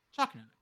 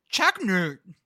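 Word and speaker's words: Choconut: Choco Nut. Okay. – Definitely. – But in Pennsylvania, it's pronounced like Chaknut.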